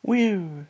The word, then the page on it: wiu: Lwaitel